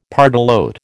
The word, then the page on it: pardalote: American English